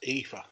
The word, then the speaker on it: Aoife: Number six, we have, you got to say her name correctly, but it's Aofi Valkyrie. How you say her name? – That's Aoife, isn't it? A-I-F-E.